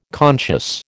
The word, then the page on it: conscious: American English